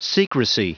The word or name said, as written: secrecy